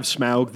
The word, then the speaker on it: Smaug: See the you'll see Smaug. There. – Yeah, they do have Smaug the dragon in Smaug.